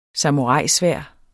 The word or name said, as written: samuraisværd